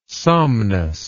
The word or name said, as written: somnus